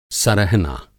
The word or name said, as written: सरहना